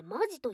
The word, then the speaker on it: 本気: 本気と書いてマジと読みます。